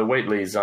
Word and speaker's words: Whateley: any guide on pronunciation? – I think it's wait the Whateleys as well. That's what the audiobook says. – Yeah. Okay. Yeah. Uh, the Whateleys are in particular I've forgotten their names already.